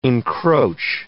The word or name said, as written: encroach